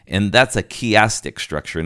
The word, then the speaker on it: chiastic: It uses what we talk about in biblical study, a chiastic structure, and you spell that C-H-I-A-S-T-I-C, chiastic structure.